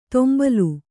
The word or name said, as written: ತೊಂಬಲು